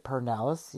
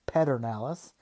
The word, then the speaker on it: Pedernales: I pronounce it Pernalis. You can pronounce it Pedernales, And I think we can all get along. I think it's fine.